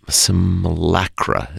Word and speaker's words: simulacra: I can never get this word pronounced pronounced right. Simul, sim, simulacra is right. Is I, I I was going to say that that's correct. – Simulacra, right? Okay.